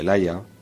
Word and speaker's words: Ligeia: La incluye en el relato de Ligeia o de Laia, como sería más correctamente pronunciarlo, pero que no pertenece a él, sino a un texto de Joseph Glanville.